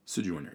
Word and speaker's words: Sojourner: Sojourner, yeah.